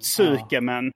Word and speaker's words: tsukemen: Men det är ganska sällan man uttalar U, va? För ett skämen, de här dipping ramen, så var det tsukemen. – Ja. – Tsukemen, men det sa att uttalat skämen. Då är det också en U för det mesta stumt.